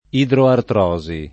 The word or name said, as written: idroartrosi